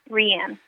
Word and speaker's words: Rianne: How is your official, like, proper name pronounced? I always thought it was Rianne. Is that true or not? – Rianne.